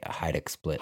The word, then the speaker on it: Hajduk Split: I would say Hajduk Split.